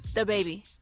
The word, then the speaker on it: DaBaby: DaBaby. – That was pretty good. – I mean, she put a lot less emphasis on duh.